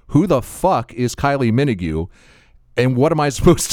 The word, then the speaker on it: Minogue: And I look it up, and sure enough, Minogue is how it's pronounced, by the way.